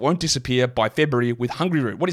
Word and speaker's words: Hungryroot: But first, I'm going to tell you about Hungry Root. Actually, it's not pronounced that way. It's pronounced Hungry Root.